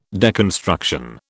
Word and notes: deconstruction: British English

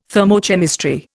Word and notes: thermochemistry: Indian

